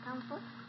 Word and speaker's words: Comfort: My name is Comfort. – Okay, how do you spell that? – C-O-M-F-O-R-T.